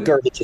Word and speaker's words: Gervich: No, but Gervich is correct, pronounced late.